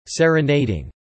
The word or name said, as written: serenading